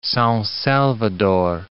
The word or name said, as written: Sao Salvador